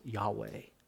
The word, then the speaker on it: Yahweh: In English, we add vowels to it and pronounce it Yahweh.